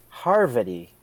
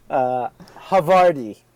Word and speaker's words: Havarti: Harvety. – Havarti.